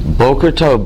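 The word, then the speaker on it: Boker Tov (בוקר טוב): Boker Tov. Be very careful, because if you mispronounce it, it can come out good cowboys.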